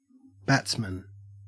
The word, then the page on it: batsman: Australian English